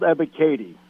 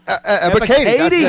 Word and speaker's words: Ebiketie: It's Arnold Ebiketie. – Ebiketie.